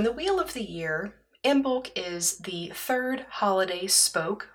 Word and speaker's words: Imbolc: Uh, the celebration can also be pronounced Imbolg with a hard G, and you may know it uh, to yourself as the Feast of St. Bridget rather than Imbolc.